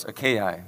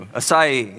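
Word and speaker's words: açaí: Anybody know how to say it? You guys are smarter than I was. Yeah, acai. I always thought it was acai, but apparently not. So acai, yes, no, acai berries.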